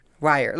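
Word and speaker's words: Weihr: How did we pronounce? – What was it? W-E-I-H-R-E? – E-R. – E-R? Wire? – Wire.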